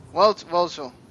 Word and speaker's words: Welchel: Thank you to Josh Welchel? – Welchel. – Damn it. I knew I was going to say it wrong.